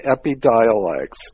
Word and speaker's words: Epidiolex: Close, yeah, Epidiolex.